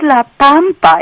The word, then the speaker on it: La Pampa: But it's La Pampa. It's an A. It's Spanish is pronounced A. Pampa.